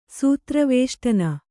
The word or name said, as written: ಸೂತ್ರವೇಷ್ಟನ